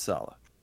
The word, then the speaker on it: Saleh: I've heard Saleh as well. I believe it's Robert Saleh. I'm not sure how he pronounces it. – It's kind of like a salad, you know? – Yeah.